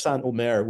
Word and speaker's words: Saint-Omer: Saint-Omer, which is the town that the trial takes place in. So nobody is being canonized in the course of the film. Spelled like Saint, so Saint-Omer. So Saint-Omer.